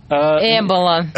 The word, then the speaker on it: Эбола: Как точно произносить-то? – Эбола. – Через Е оборотное, да? – Через Эбола.